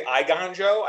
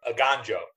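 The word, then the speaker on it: Eiganjo: I've heard people say Iganjo. I guess it can go either way. I pronounce it Iganjo or Aganjo.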